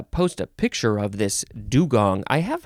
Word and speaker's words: dugong: Dugong. You can say, how do you pronounce this? There's a couple of ways. Dugong, I think. Like a gong that goes bong. Yeah, dugong or dugong. D-U-G-O-N-G.